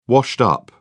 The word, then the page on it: washed-up: British English